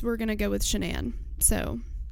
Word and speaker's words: Shanann: It was originally pronounced Shannon, and she was married before she married Chris. And after that divorce, she kind of started just pronouncing it Shanann. And like everybody now pronounces it Shanann, even Chris and her friend Nicole. But her parents still say Shannon, so it's confusing.